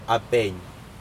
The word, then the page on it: apeiN: Aneityum